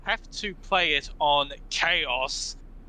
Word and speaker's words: Chaos: Like, I think I talked about the difficulties before, and, like, once you beat the game, the normal game, you unlock Chaos difficulty, which... – You said it wrong. – ...starts at level one. – You gotta say it right. Yeah, thank you, yes. Chaos!